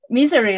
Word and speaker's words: Missouri: Missouri, right? – Your pronunciation of that is actually pretty funny and good. Because Missouri, like misery bad, but Missouri. Yeah.